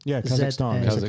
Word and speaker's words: Kazakhstan: Kazakhstan. – Yeah, Open on the Challenge Tour. – Kazakhstan.